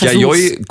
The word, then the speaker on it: Yayoi: Ach, Yayoi Kusama.